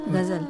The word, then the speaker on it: Ghazal: Ghazal, yes.